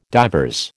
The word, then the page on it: divers: American English